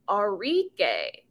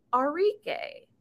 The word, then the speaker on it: Arike: Like A-R-I-K-E is Arike, not Arike, not R-E-K. It's Arike.